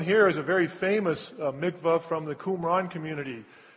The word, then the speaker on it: mikvah: Sometimes you hear it pronounced mikveh, but I think probably mikvah is probably closer.